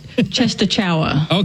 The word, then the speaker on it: Częstochowa: It looks like Częstochowa. – Okay, yeah, very good.